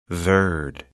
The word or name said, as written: Verde, Cape